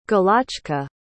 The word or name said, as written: galochka